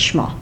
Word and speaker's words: Shema: It's Shema. so uh, the transliterated spelling would be S H E M A. Now in Hebrew, the E is pronounced like a long A, so it's Shema.